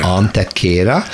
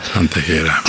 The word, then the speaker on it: Antequera: és nem tudjuk, hogy hogy kell kiejteni, úgy van leírva, hogy Antequera, igen. Antekéra. Antekéra.